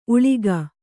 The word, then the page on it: ಊಳಿಗಿ: Kannada